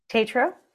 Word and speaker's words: Tetreault: Tetreault.